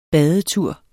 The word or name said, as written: badetur